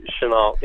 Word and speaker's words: Shenault: I believe Shenault is how you pronounce that. – I checked it, yep.